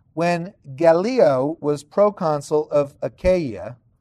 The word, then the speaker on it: Achaia: Okay, now Achaia, like I said earlier, that's the correct pronunciation now, is the Roman province in southern Greece.